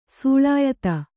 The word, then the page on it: ಸೂಳಾಯಿತ್ತ: Kannada